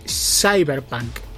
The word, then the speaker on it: Cyberpunk: Se dice así, se dice Cyberpunk.